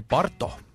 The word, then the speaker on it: Baruto: Baruto. Ehk siis, kuidas hääldati, Parto. – Parto või? – Jah.